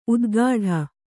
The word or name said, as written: ಉತ್ಕಟ